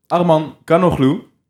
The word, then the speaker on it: Arman Canoglu: En in ons midden, Arman Kanoglu. – Ja, helemaal goed uitgesproken.